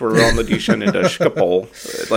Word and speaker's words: Schiphol: I thought it was Schiphol. I have heard a K in there sometimes. But you apparently say it the right way, which is the Dutch way of Schiphol. – Schiphol.